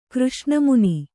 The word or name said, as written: ಕೃಷ್ಣಮುನಿ